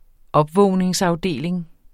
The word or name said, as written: opvågningsafdeling